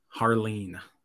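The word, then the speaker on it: Jarlín: Harleen. That's how he prefers it pronounced.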